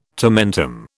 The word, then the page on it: tomentum: British English